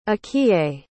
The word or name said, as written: akiye